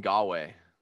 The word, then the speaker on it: Ngakoue: Oh, Yannick Ngakoue.